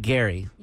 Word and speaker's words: Gerry: G-E-R-R-Y. – I thought it was Jerry, but it's spelled the G. – His name is Jerry, but pronounced like Gary.